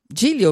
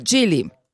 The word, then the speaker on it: Geely: Diniz fala hoje sobre o novo rival do BYD Dolphin e Jelly. Jilly, ou melhor. É difícil de pronunciar, né? Mas a gente vai aprender a falar. Jilly.